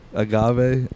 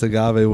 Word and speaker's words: agave: What'd you say earlier? Agave? It's pronounced agave. What did you say? – Agave.